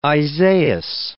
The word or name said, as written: Isaias